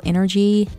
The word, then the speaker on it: NRGY: And for those of you who are new, you spell energy, N-R-G-Y. Once again, my name is spelled N-R-G-Y.